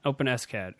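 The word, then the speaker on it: OpenSCAD: Open SCAD. Okay. – That's pronounced open SCAD as well.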